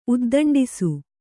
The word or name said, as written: ಉದ್ದಂಡಿಸು